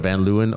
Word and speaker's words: Van Leeuwen: Van Leeuwen.